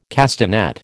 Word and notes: castanet: American English

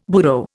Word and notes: burro: Indian

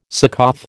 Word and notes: Succoth: American English

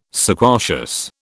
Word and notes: sequacious: British English

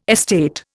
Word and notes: estate: Indian

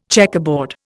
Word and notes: checkerboard: Indian

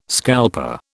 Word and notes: scalper: British English

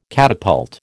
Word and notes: catapult: American English

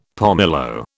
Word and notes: pomelo: British English

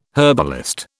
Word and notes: herbalist: British English

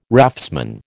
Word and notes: raftsman: American English

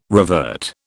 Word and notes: revert: British English

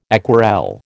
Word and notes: aquarelle: American English

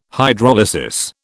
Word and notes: Hydrolysis: British English

